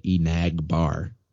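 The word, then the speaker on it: Enagbare: He said Enagbar. I don't know if it was Barre or Bar, but Enag. Bar. Like Nag's Head Beach or something.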